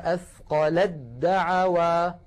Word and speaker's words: أثقلت دعوا: لذلك نحول التاء إلى دال ثم ندغم الدال في الدال فيكون النطق هكذا، فلما أثقلت دعوا، لاحظها أثقلت دا، يعني ما بقي تاء